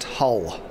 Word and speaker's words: Hull: In response to that, we say, it's pronounced Hull.